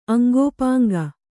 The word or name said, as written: ಅಂಗೋಪಾಂಗ